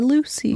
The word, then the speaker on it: Loosy: But it's spelled L-O-O-S-Y, Lucy.